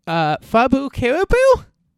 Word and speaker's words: FabuCaribu: I'm your host, and with me this week is FabuCaribu? Did I say that right? – Yes, that's right.